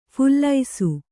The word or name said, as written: ಫಲಾಸಿ